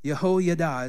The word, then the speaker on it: Jehoiada: Verse 4 says, now in the seventh year, Jehoiada is how you actually pronounce it. Jehoiada sent and brought the captains of the hundreds of the Karites.